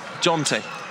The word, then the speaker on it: Jonty: Jonty. – Jonty. – Yeah. – Spell that. – J-O-N-T-Y.